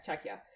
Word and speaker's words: Czechia: Czechia? Is it Czechia? – Yes. – Okay, Czechia. Because it's the Czech Republic.